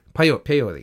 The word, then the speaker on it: Paoli: Where he does not have a pleasant reunion is with Paola. Am I saying that right? – Paoli, excuse me. – Paoli, I think.